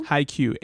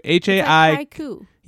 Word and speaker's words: Haikyuu: That's how you say it, Haikyuu? – Haikyuu. – Haikyuu. – Yeah, Haikyuu. And I think there are two exclamation points.